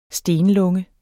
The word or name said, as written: stenlunge